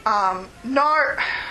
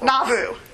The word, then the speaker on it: Nauvoo: And so they fled to Illinois, where they built the town of Nauvoo. I can't ever pronounce it right. Thank you. Oops. Nauvoo.